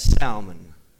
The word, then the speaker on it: Salmon: Nashon begat Salmon. Do y'all pronounce the L in Salmon? No. Not when it's fish, but I am going to pronounce it here when it's a proper name.